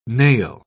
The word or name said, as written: nail